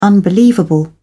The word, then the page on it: unbelievable: British English